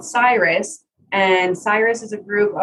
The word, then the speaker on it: SYRUS: So then the project that I thought was really cool that we talked about is it is it Sirius? Did I say that correctly? It's called SYRUS.